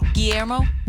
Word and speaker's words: Guillermo: How do you say his name? – Guillermo. – Yeah, Rodriguez.